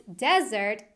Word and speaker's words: desert: And desert, spelled like dessert but pronounced desert, is a very dry area.